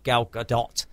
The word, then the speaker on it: Gal Gadot: Gal Gadot, that you have to pronounce it like that because she's Israeli.